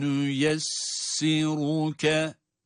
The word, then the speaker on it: nüyessiruke: Ra harfi hariç olmak üzere bütün harfler ince okunuyor. Ama o aradan çıkan kalın bir ra sesi öyle bir ahenk, öyle bir güzellik katıyor ki kelimeye. Bakınız. Ve nüyessiruke. Ve nüyessiruke. Müthiş bir insicam, müthiş bir güzellik. İşte burada ra da kalın.